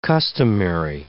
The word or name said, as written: customary